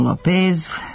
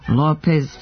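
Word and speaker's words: López: Pa godinama izgovaramo Lopez, Fernandez, Velasquez umjesto Lopez, Fernandez, Velasquez, iako je u španskom lako vidljivo mesto akcenta jer se obeležava u pisanju.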